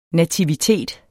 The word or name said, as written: NATIVITETS